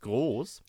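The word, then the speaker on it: Grosz: Also A, ich habe nicht mal Grosch ausgesprochen, weil man so komisch schreibt, aber ich höre es groß.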